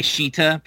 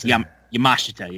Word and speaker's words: Yamashita: I was just going to say, because, yeah, I always want to say Shita, but I know that's not right. It's Yamashita, yeah.